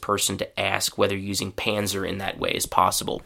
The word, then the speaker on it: Panzer: The German word, German pronunciation, sorry, is Panzer. And so, in English, we just, as I said, we say Panzer,